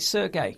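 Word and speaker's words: Serge: Is it Sergey? – Sergey, yeah.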